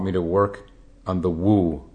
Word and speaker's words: Wu: And when we refer to the Mu koan, the word, the original Chinese, would be pronounced Wu, W-U.